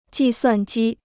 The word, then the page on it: 计算机: jì suàn jī